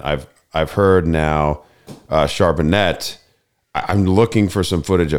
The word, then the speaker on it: Charbonnet: Well, I mean, everyone seems to be saying Charbonnet.